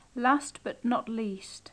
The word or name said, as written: last but not least